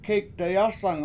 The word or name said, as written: kEEk dayAsang